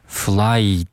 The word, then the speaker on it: flight: to Kabul，这个里面也涉及到了一个比较常见的双元音，就是 flight 中间的这个 i 的发音，它读作 i i flight。